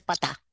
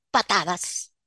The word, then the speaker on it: patadas: sino sacar a patá, sacar, perdón, que dije patá, no se dice patá, se dice patadas a Ariel Henry.